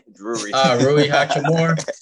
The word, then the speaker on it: Rui Hachimura: So I felt like maybe him and I can't never say his name right. So I just called him Hirachi, the one off the bench. – Rui Hachimura.